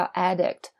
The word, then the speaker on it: addict: 好，那这边还有另外一个知识点，就是这个名词形式不光是 addiction 一个上瘾，还有一个这个 addict 长相不变，但是呢这个发音变了， addict 现在重音移到了前边，这个叫瘾君子，指的是就是不管干什么上瘾的人是吧。